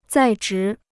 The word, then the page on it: 在职: zài zhí